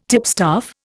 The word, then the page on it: tipstaff: Indian